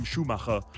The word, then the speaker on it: Schumacher: We already started he is actually German, so Tim Schumacher is the correct pronunciation.